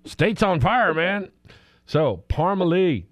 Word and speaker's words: Parmalee: It's Parmalee, with the emphasis on the Lee.